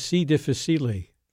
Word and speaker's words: C. difficile: So in our break between, you correctly pointed out that the pronunciation is C. difficile.